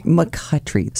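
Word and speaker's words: McCoughtry: McCutry.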